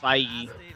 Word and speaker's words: Feige: Kevin I think it's pronounced Feig, is it? – No. – I thought it was Feige.